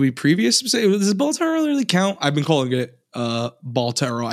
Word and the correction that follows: Balatro: I don't know if that's how it's pronounced. It's Balatro.